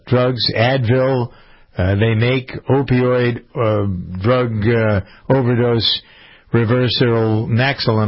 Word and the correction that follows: naloxone: am I saying that right? Naloxone, I guess it is, N-A-L-O-X-O-N-E, anyway.